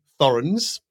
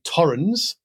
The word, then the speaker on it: Thorens: So Americans say Thorens and Germans say Torrens. So I bought a Thorens turntable.